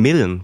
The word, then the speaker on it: Milne: Oder, nee, Milne wird er ausgesprochen.